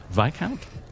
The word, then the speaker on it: Viscount: Viscount? – Remember, discount Viscount. – Discount. It doesn't make any sense. – Yeah, that doesn't help. – I remember because it doesn't rhyme with discount.